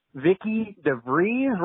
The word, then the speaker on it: DeVries: Number 3, 17-inch, Vicky DeVries, rhymes with cheese, when we talked about the star ceremony.